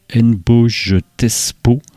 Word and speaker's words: Nboj Tespo: Il a un alias ou un projet qui répond au nom de Nboj Tespo, N-B-O-J et Tespo, comme ça se prononce.